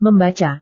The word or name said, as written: membaca